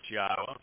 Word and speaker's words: Yahowah: And it's Yahowah. For those who are the first-time listeners, Yahowah's name is exceedingly easy to pronounce. It's comprised of four vowels, one of which is repeated, Yod, Hey, Wah, Hey. The Y is pronounced like the Y in English. In English, it is a consonant vowel. The Y is. The Hey is pronounced as an Ah.